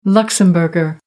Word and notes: luxembourger: American English

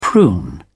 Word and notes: prune: British English

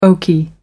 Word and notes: oaky: American English

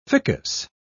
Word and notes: ficus: British English

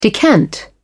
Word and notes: decant: British English

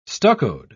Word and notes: stuccoed: British English

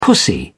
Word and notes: pussy: British English

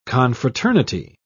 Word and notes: confraternity: British English

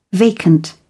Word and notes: vacant: British English

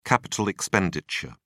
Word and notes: capital expenditure: British English

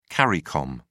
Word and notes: caricom: British English

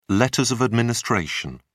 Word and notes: letters of administration: British English

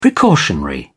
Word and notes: precautionary: British English

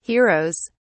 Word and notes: heroes: British English

